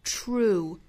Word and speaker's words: true: 一定要注意舌头卷度的位置跟我们汉语那个触的这个吃的发音是完全不一样的，应该是一个的发音，同时嘴唇是要向前嘟嘟成一个喔的一个唇形，两个同时用力是 true true，所以不是触而是 true true true，一定要注意有一个 er 的发音。